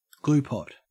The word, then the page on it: gluepot: Australian English